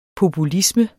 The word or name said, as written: populisme